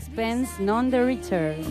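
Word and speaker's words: Sixpence None the Richer: Sixpence, none the richer.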